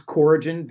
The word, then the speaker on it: Corigin: So it, it, it was Corigin Ventures uh, with a hard C. A lot of a lot of people mispronounce that.